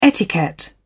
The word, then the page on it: etiquette: British English